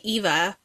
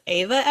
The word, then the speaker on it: Eva: And I see Eva because that's how it's spelled, but the audiobook narrator pronounces it Ava Evergreen. So I guess I should probably start saying it correctly.